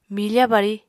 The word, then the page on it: mifa bari: Apatani